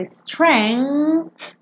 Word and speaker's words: strength: Esse G aqui é mudo. Então você pensa strength e bota aquele som.